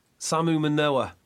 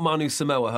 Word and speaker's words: Samu Manoa: Let's get his name right. Not Manu... Samu Manoa. There we go. Not Manu Samoa, as I like to call him.